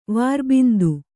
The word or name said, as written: ವೈರಬಂಧ